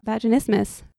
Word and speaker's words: vaginismus: About vaginismus. – Yeah. – Rhymes with Christmas.